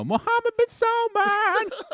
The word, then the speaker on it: Mohammed bin Salman: Well, I agree with someone on Twitter who said that you should pronounce his name to the tune of the song American Woman. You know, Mohammed bin Salman.